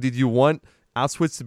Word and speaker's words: Auschwitz: they go, and now I wonder if I'll shock you on this one or not, but they go to Auschwitz. Yeah, I'm saying that right? – Auschwitz, yeah.